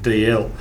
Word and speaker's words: Dalziel: I think it's a bit like Dalziel and DL, or Menzies and Mingus. Race three at Caulfield on Saturday.